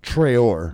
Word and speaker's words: Traore: How do you pronounce his name again? Johan. – Johan Traore. – Traore. – Yes. – Traore. Okay. Yes. – Not Traore.